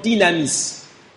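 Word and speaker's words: dynamis (δύναμις): Le mot « revêtu » ici est un mot grec que l'on prononce « dynamai » ou encore « dynamis ».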